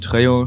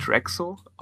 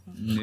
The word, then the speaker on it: Trejo: Trexo ausgesprochen? – Trejo. Nee, Trejo. Im Übrigen Trejo.